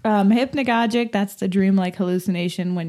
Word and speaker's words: hypnagogic: Hypnagogic. – Yeah, hypnagogic.